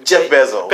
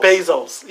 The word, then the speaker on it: Bezos: I was Jeff Bezos. – Bezos, yes. – Yes. – Bezos.